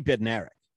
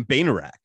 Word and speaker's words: Bednarik: There's a sprinter named Kenny Bednarik. Okay. – Kenny Bednarik. – Regular college kid, very good. He won the silver medal in the 200 meters. He was calling him Bainerak, like the D is silent.